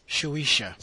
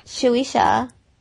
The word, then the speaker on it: Shueisha: It's Shueisha. – Shueisha. Mm-hmm. Can I say Shueisha, and you can say tomato, and I can say tomato? – If you're going to say it right.